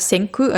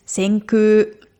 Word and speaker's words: Senkū: Senkü, na, Vorsicht, die Aussprache ist Senkü, also ein langes U. Also nicht so, wie man schreibt Senkü, also das K und U, sondern eigentlich ist Senkü.